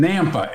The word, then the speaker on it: Nampa: It is going to be in Nampa, NAMPA, if that's how you pronounce it.